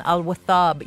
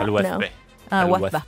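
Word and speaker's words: Al Wathba: Instead of gallivanting across the Emirates, we had Laura, who was at the Sheikh Zayed Festival in Al Wathab yesterday. Have you ever been to that? – Al-Wathab. – No. – Yeah, that's how you pronounce it.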